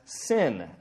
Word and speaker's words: Sin: The moon god was known by the ancient dialect as, this is, seems funny, but known as Sin, S-I-N. That's the way they would pronounce it and spell it.